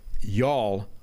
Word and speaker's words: y'all: By the way, y'all is the correct Texan phrase, but my wife says you have to put the emphasis on the Y like a long U. Phonetically, it's like you all with a deep throat U before an emphasized Y right before all.